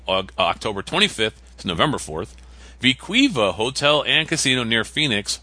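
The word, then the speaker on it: We-Ko-Pa: And I made sure that's how you say it. I actually watched a video about the property the other day. So it's good. Viquiva, yeah.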